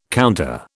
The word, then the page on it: counter: British English